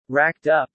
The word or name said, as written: racked up